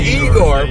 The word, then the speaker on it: Ygor: And then, oh my God, the best performance is Bela Lugosi as Igor, but it's spelled Y-G-O-R, by the way.